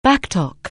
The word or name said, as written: back talk